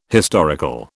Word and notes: historical: British English

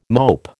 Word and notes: mope: American English

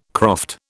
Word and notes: croft: British English

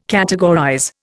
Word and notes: categorize: Indian

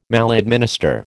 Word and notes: maladminister: American English

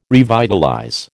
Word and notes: revitalize: American English

